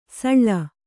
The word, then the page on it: ಸಳಿ: kannada